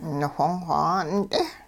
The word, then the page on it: niwhong-xw 'aa'unt'e: hupa